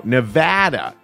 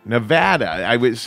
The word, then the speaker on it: Nevada: That is on, or no, Nevada. Nevada. A fan wrote into me and said, you're pronouncing it wrong.